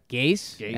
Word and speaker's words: Gase: Gacy, is that how you say it? – Gase. Adam Gase.